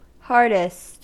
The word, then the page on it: hardest: American English